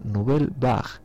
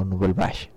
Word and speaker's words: Nouvelle Vague: Dime Lucía que este grupo que sonó en antes pronunciase Nouvelle Vague, no Nouvelle Vague.